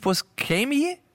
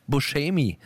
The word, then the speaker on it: Buscemi: Schrecklicher Titel, ein Film mit Steve Buscemi. Ach, ehrlich, so wird ausgesprochen. Wir waren uns gerade unsicher, aber viele sagen ja Buscemi. Und das ist natürlich völlig falsch. – Oder Buscemi.